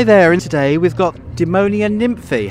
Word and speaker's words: Demonia Nymphi: We were also lucky enough to get some time to chat with Spiros and Evie from Demonia Nymphi.